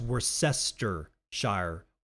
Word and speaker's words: Worcestershire: If you've ever wanted to know how to pronounce Worcestershire sauce, it's actually pronounced Worcestershire. Worcestershire. The Shire of Worcester.